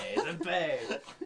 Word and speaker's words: Sempé: Yeah, Sempé. – Sempé.